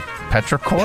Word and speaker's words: petrichor: How do you pronounce this? – Petrichor. – Petrichor? Okay, so petrichor.